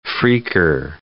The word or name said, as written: phreaker